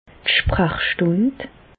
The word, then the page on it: d' Sprachstùnd: Bas-Rhin; Reichshoffen